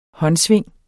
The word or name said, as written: håndsving